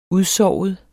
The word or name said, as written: udsovet